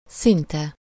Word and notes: szinte: /ˈsintɛ/

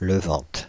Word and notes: levante: [lə.vɑ̃t]; /lə.vɑ̃t/

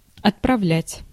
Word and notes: envoyer: [ɑ̃.vwa.je]; France

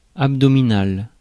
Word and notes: abdominal: American English

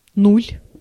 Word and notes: нуль: /ˈnulʲ/; /nul/

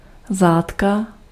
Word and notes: bouchon: [bu.ʃɔ̃]; France

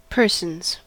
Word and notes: persons: /ˈpɜː.sənz/; /ˈpɝ.sənz/

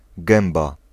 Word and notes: trap: American English; [tɹræp]

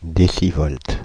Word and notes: décivolts: /de.si.vɔlt/; France (Île-de-France)

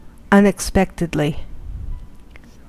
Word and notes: unexpectedly: American English; /ʌnɪkˈspɛktɪdli/

